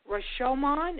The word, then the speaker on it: Rashomon: Rashomon.